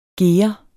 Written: gere

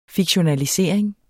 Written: fiktionalisering

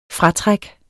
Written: fratræk

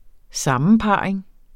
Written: sammenparring

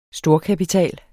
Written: STORKAPITAL

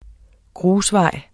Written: grusvej

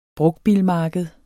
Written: brugtbilmarked

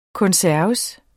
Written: konserves

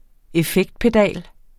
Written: effektpedal

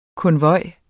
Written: konvoj

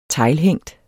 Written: teglhængt

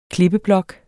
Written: klippeblok

